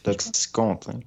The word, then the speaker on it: OxyContin: OxyContin.